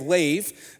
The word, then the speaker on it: lev: So the Hebrew word for heart in Proverbs 4:23 is lev, is the way it's pronounced.